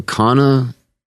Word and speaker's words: Kanna: Is that Kanna, how you say it? – Yes.